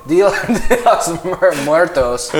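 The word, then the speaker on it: Día de los Muertos: So, Dia de los Muertos is how you say it.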